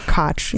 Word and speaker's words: Koch: I thought it was Coke. I don't know. Whatever. – Koch. – Koch. Who knows? – You know, I never pronounce anything right.